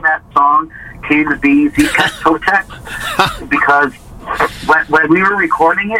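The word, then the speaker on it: Xipe Totec: And then the next one, I'm going to try to pronounce this best I can, track 6. Zipe Totec? – Yeah, it's Zipec Totec. – Okay, Zipec Totec.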